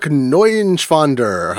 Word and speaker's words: Neuschwander: It's Eric Neuschwander, how right. I pronounce. Yeah, I'm pronouncing it Germanly.